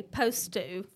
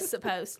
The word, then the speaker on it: supposed: Well, so many people say post to for yes. that instead of supposed.